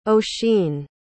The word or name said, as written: ossian